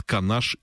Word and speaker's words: Канаш: Это Мариинский Посад, Канаш и Шумерля.